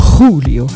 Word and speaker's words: Julio: Julio, habe ich extra gesagt, Julio. Wie Gijón.